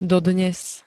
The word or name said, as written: dodnes